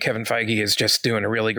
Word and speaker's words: Feige: I did not realize that was how to pronounce his name until just the other day. I heard Tom Holland say it in an interview. I'm like, what? It's not Fague.